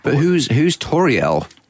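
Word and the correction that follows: Tauriel: Tariel. – Sorry? – Tariel. – Tariel. – I got corrected. That is the correct Elvish pronunciation, although it's a new character for the film. – Right. Tariel.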